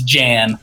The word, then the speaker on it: Jan: If he was Swedish or like Croatian, then it might be Jan. But but no, he's pretty sure he's from PA, so it's Jan. – It's Jan. All right, hard J there.